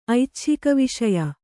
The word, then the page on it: ಐಚ್ಛಿಕವಿಷಯ: Kannada